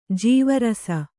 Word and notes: ಜೀವರಸ: jīva rasa

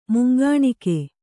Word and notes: ಮುಂಗಾಣ್ಕೆ: mungāṇke

